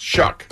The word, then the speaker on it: Shough: His last name is spelled S-H-O-U-G-H, but it's pronounced Shuck.